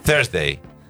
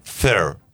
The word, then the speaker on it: Thursday: Vamos a separar la palabra en dos. Thur. – Thur. – Agregué una vez. Thursday.